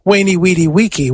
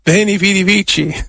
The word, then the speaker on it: veni, vidi, vici: Well, if you pronounce it with what we think was the classical pronunciation, it's wainy, weedy, weeky, which sounds a little bit like a summer camp. Camp wainy, weedy, weeky. No, veni, vidi, vici. So I would go with the Italian pronunciation.